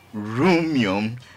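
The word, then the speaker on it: Rumion: And like he says, Roomion rhymes with what? Room.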